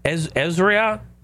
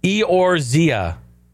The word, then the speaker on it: Eorzea: It's going to center on the original story set in the world of Eorzea, Ezria. It's pronounced Eorzea. What is it? Eorzea.